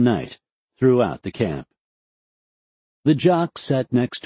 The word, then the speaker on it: JOC: Colonel Mulholland's Joint Operations Center, or JOC, pronounced Jock,